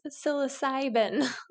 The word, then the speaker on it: psilocybin: Some of these phone calls I do end up like psilocybin. Some lady's like, did you say socks? I was like, no, psilocybin.